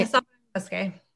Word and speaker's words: Sauvignon Musqué: Yeah, Sauvignon Musqué.